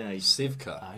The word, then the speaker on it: Cywka: C-Y-W-K-A. – Sivka.